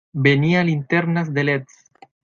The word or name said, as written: linternas